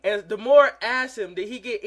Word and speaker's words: D'Amore: It's Scott D'Amore, okay? Me and you have been saying Scott D'Amore for every week. It's pronounced D'Amore.